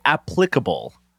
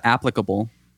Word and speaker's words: applicable: Speaking of applicable, I have a hard time saying that because I always want to say applicable. – What about applicable? – Is that a word? Applicable? Is that just another way of pronouncing it? – Applicable and applicable? I think you can get away with it.